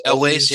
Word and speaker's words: Elwes: Elwes? – Elwes, yep.